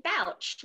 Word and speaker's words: Bouch: So it's actually Bouch, like rhymes with couch.